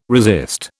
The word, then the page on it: resist: British English